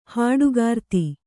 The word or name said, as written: ಹಾಡುಗಾರ್ತಿ